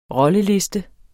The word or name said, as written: rolleliste